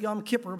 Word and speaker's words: Yom Kippur: And I think they pronounce it Yom Kippur, but that sounds so wrong to me. But either way, Yom Kippur.